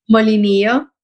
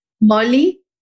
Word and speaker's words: Molinillo: Molinillo. – Molo, say it again. – Molinillo.